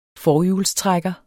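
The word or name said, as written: FORHJULSTRÆKKER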